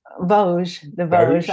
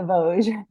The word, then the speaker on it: Vosges: And then you go into, I don't know if I can pronounce this right, the Vosges. I didn't pronounce it correctly either for quite a while. But but it's the Vosges.